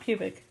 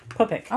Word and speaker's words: pupik: Not pubic. Pupik.